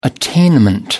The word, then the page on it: attainment: American English